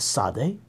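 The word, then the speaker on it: tsade: The word consists of three Hebrew consonants, a sade, a picture of a man on his side, pronounced ts or t like a ts together, a lamed, the Hebrew L, a picture of a staff, and a mem, a picture of water, the Hebrew M.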